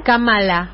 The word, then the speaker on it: Kamala: Kamala. Kamala se pronuncia, con, tilde en la primera Eso lo, leí hoy, ¿eh?